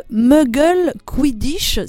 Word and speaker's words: Muggle Quidditch: Euh, je suis heureuse d'accueillir aujourd'hui euh, Jérémy Delmotte, licencié de ce sport, le Quidditch moldu ou encore le Muggle Quidditch, si je le prononce bien. C'est comme ça, Jérémy Bonjour. – Bonjour, oui, c'est comme ça, oui. – C'est comme ça qu'on dit.